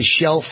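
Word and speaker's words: Chele: Her website is Shell Farley for Senate. Now, Shell is spelled C-H-E-L-E, C-H-E-L-E, Shell Farley for Senate.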